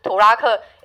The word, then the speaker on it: 土拉客: 最后一个意思，我们要用台湾的母语，台湾的呃母语之一，呃宜兰的母语之一来发音，就是土拉客，呃也是拖拉库的意思。